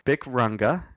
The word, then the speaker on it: Bic Runga: At my gig this weekend, I covered Guster, Bic Runga.